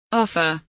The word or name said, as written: offer